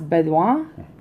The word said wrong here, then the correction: Beaudoin: Let me see. No, Baudouin. – Baudouin. – Yeah, that's a French name. B-E-A-U is Beau and D O I N. Baudouin. Thomas Baudouin. Baudouin.